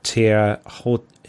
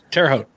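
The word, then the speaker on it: Terre Haute: with the race ending at Tierhoute Regional Airport. I probably absolutely butchered that. – Tierhoute.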